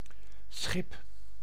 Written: boat

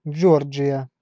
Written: Georgia

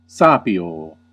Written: discern

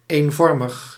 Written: uniform

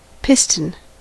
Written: Kolben

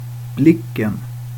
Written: blicken

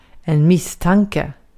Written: misstanke

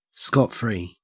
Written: scot-free

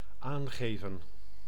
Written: aangeven

